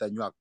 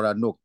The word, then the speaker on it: Anuak: What's the correct pronunciation? Is it Anaks or Anorks? – You can go with Anuak or Anok, because it's hard even for people, for non-Anuak it's hard, it's just Anuak.